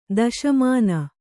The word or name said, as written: ದುಸ್ಮಾನ